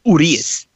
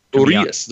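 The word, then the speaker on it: Urías: So, you know, the last 4 innings of Game 6, Gonzalez, Gratterall, Urias. I don't know how to say it right. 4 innings. – I think it's Urias. – Urias. There you go.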